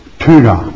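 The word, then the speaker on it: towdah: It's T-O-W-D-A-H. It's pronounced in that tongue, tuda, which is to say the putting forth of a hand.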